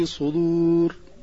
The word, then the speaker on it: الصدور: الأولى تتعلق بنطق القارئ لكلمة الصدور في الآية 19 من سورة غافر، حيث نطقها بقلب الصاد إلى سين والدال إلى طاء أو تاء. والصحيح أن الكلمة في الآية الكريمة بالصاد وبنطق الدال صريحة.